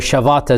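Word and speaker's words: Shabbat: So the Jewish Sabbath, or Shavat as they pronounce it, it is something that the Jews observe throughout the entire year on the seventh day of the week, which is Saturday.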